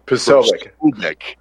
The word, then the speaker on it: Posobiec: Jack Prose. I can never say his name. – Posobiec. – I always try to say it too fast, but Jack Posobiec.